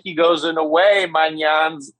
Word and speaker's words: Maignan: How much of an impact will Maignan's absence have on Milan? – I think the pronunciation was good.